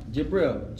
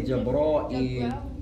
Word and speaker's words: Jibreel: And you can say I-I-I-E-E. Jibril. Elongation. – Jibril or Jibril. – If you say it in Arabic, it's elongation. Jibril. If you say it in English, you're going to have one I. Jibril. J-I-B-R-E-E-L. Jibril. You can also say Jibra'il. You can pronounce it two ways.